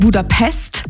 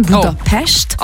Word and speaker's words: Budapest: Also erstens appreciaten wir das schon mal, wenn man nicht Budapest sagt, sondern Budapest. – Oh. Okay, weil das S immer ausgesprochen wird wie ein Sch.